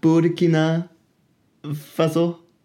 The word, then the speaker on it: Burkina Faso: Burkina Faso. – Ja, flot. – Er det rigtigt udtalt? – Det var lige som det skulle være.